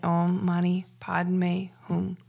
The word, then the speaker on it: Om Mani Padme Hum: It's also pronounced Om Mani Padme Hum with a H-U-N-G. That's just a difference in accent and pronunciations. But I am saying it Om Mani Padme Hum.